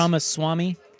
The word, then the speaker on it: Ramaswamy: Yeah, Ramaswamy.